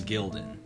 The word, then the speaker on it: Gildan: They literally are buying, like, Glidden hoodies and just putting their brand on it. – I think it's Gildan. – Or Gildan, yeah, they're Gildan hoodies.